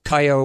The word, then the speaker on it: Kyo: That's K-Y-O. Kayo, or it's pronounced Kyo in Japanese.